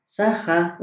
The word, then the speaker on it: Sakha: To you, for example, is it the right pronunciation of the language, Sakha? Is it like that we have to pronounce it? – Yes, that's correct. Sakha language.